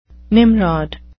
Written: nimrod

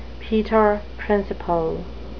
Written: peter principle

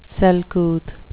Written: selcouth

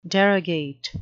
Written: derogate

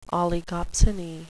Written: oligopsony

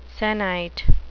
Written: sennight

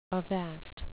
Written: avast

